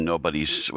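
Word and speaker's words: Knobodies: So if that was the case, how did you get into writing Nobody's? It starts with a K, that silent K.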